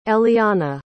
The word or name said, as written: ellianna